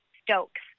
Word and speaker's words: Stokes: But my name is spelled M-A-R-I-Y-A. Stokes, S-T-O-K-E-S, and I'm on Instagram and Facebook and Twitter and all the things.